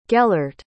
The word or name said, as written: gellert